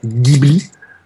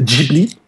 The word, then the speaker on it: Ghibli: Ghibli, c'est un mot italien. Ça se prononce avec un G dur. On dit bien Ghibli même si certains japonais le prononcent avec un G mou et disent Ghibli, cependant, à plusieurs reprises, Miyazaki lui-même, en tant que fan d'aviation, a corrigé la prononciation et a rappelé qu'on disait Ghibli.